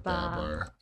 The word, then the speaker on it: Babar: That's the American pronunciation. The British pronunciation, my friend, Baba.